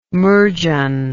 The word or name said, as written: murgeon